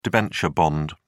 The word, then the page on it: debenture bond: British English